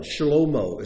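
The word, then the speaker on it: Shlomo (שלמה): Solomon, Shlomo, is how his name is pronounced.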